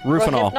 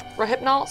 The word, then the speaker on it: Rohypnol: Rohypnol. – Yeah. – Rohypnol.